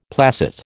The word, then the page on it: placet: American English